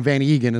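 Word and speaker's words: van Eeghen: Also, so in that soundbite with Alec Ingold there, he called him Mark Van Egan. And Van Egan is what we were always, the way we pronounced it growing up.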